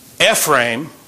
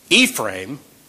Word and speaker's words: Ephraim: So another thing there is the first word in verse 17, Ephraim is pronounced by some people as Ephraim.